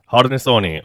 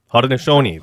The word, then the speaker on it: Haudenosaunee: Haudenosaunee. – Okay. Haudenosaunee.